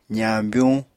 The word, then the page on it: zañbyuñ: apatani